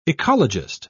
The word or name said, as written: ecologist